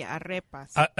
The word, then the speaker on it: arepa: A Venezuelan inspired now you've got to tell me how to pronounce this. Arepa or arepa? – It'd be shredded pork, A-R-E-P-A. – It'd be arepas. – Arepas? – Yeah.